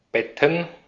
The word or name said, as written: Betten